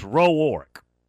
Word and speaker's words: Rourke: Friend of mine, I think I can mention his name, Eric Rowark, and it's spelt like Rourke, but it's pronounced Rowark, because that's the way he pronounces, and it's his name.